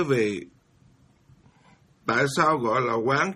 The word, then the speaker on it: Quán: Cái chữ quán ở đây đó là, các vị phải biết rằng nó là đọc thì nó cùng nghĩa với từ quan. Mà tại sao mình không đọc là quan mà mình lại đọc là quán?